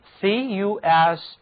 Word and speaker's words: focus: 和 center 意思相近的词有 focus, focus, focus。在这里字母 u 读音是倒写的元音，这个词做名词表示中心、焦点。